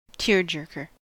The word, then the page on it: tearjerker: American English